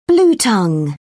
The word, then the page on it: bluetongue: British English